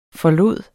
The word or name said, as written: FORLADES